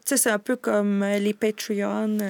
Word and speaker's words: Patreon: Là, je sais pas, je vais bien le prononcer, là, Patreon. – Ouais, Patreon.